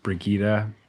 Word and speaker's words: Brigida: Uh, as I understand it, her name would have been pronounced Brigida. Okay. Uh, when she was alive, and in Swedish, and yeah.